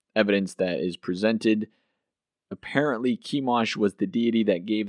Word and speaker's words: Chemosh: Looks like Chemosh, but it's pronounced Chemosh. C-H-E-M-O-S-H.